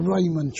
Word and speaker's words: Rahman: The Raymond track. I think it's spelled R-A-H-M-A-N, the Raymond track, which is in fact where the breakthrough took place.